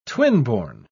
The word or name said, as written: twinborn